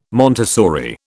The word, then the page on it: Montessori: British English